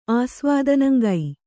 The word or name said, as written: ಆಸ್ವಾದನಂಗೈ